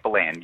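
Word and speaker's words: Ballan: It's pronounced Balland, is it? – Yep, Balland, yes.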